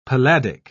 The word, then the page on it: palladic: British English